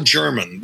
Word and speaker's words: Jermyn: I said, man, this guy's name is Paul German. It's spelled J-E-R-M-Y-N.